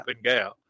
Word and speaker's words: Fine Gael: He is joining the other two parties, Fine Gael and Fianna Fáil. Did I pronounce them right? – Fianna Fáil. And Fianna Gael. Yeah. – Fine Gael.